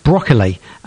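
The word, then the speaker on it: Brogeal: Now, this band, I've been told, is pronounced Broccoli in its Gaelic. B-R-O-G-E-A-L. I'm told it's Broccoli.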